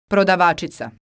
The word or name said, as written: prodavacica